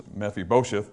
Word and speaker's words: Mephibosheth: Referring to, again, we pronounce it Mephibosheth all the time, but the actual pronunciation is Mephibosheth.